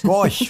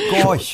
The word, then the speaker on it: Gorch: Denn äh, was ich kann George, George Fock nicht aussprechen. Gorch. George, Gorch. Gorch. Gorch. Das ist ganz einfach.